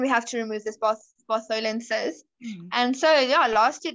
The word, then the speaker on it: Bartholin: I keep saying it wrong. I think it's Bartholinian cyst or whatever. – Bartholin.